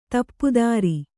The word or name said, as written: ದಪ್ತರು